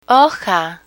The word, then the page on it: hoja: Spanish